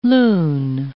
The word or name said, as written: loon